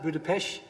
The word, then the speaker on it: Budapest: We travelled from Amsterdam in the Netherlands to Budapest, notice how I pronounce that, Budapest, in Hungary.